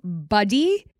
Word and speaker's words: Buddie: Okay, so it's Buddy, but I'm going to spell it for you because obviously that doesn't tell you very much. So Buddy, B-U-D-D-I-E.